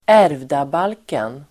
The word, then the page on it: ärvdabalken: [²'är:vdabal:ken]